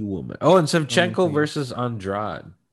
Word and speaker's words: Andrade: She's Andrade. – And Jessica Andrade. That's how they pronounce it.